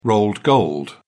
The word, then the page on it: rolled gold: British English